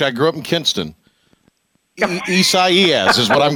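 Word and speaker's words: Isaías: I believe this pronunciation is a Portuguese, because the Spanish pronunciation is a little bit different. But the English name Isaiah is what this name with the letter I is for this particular year. So I believe it's the Portuguese pronunciation. So Isaias is the way I'm pronouncing it, and I hope that's correct, Patrick, but I give you a high score as well.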